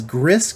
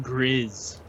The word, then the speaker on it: Gris: A lot of you people might pronounce it as Gris, because it's spelled G-R-I-S, but it's called Gris. Check it out.